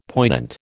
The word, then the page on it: poignant: American English